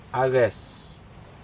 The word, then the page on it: aces: Aneityum